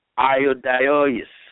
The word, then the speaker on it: Ioudaios: Let me say it right, iodeois. Now, it's spelled as I-O-U-D-A-I-O-S. How do you say that? Iodaios, okay?